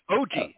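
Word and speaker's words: Ojii: And I think that's Ouija because it's spelled like a Ouija board. O-J-I, Ouija. – O-G. – Right? – It's O-G. – It's O-G, yeah. Yeah, that's a great episode.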